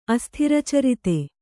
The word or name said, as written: ಅಸ್ಥಿರಚರಿತ